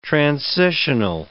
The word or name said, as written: transitional